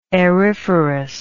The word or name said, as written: aeriferous